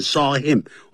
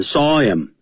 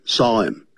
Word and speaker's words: saw him: 但 blow off 是炸掉离开不见了。他一只腿这整个被炸掉了。The first time his mother saw him, 我不念 saw him, 我念 saw him, 那个 H, 我不念念成 M 的音念成 M, saw him. 我不强调那个 him, 我强调 saw.